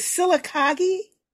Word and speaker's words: Sylacauga: Is it Sylacauga? – That's close enough.